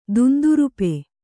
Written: ದುಂದುರುಪೆ

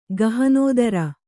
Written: ಗಹನೋದರ

